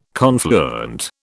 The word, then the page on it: confluent: British English